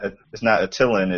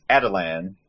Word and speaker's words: Attilan: And it's, as far as the animated show pronounced it, it's not Attilan, it's Adelan.